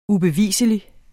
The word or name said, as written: ubevislig